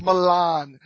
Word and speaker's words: Milan: You want to get correct pronunciation? It's Milan. It's spelled Milan, and everybody pronounces it Milan.